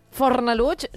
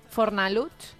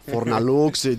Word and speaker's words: Fornalutx: Que t'anava a dir, Fornalutx no deu ser fàcil de pronunciar ni pels castellano parlants de la península, no? Fornalutx. – Deu dir Fornalutx. – Clar, perquè entre aquesta X final...